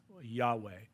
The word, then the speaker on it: Yahweh: It's a Hebrew word, and in Hebrews you don't get vowels, so the pronunciation is based on the context. And sometimes vowels get inserted just for the sake of pronouncing it, but Yahweh has no vowels. It's YWH, Yahweh.